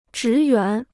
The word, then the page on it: 职员: zhí yuán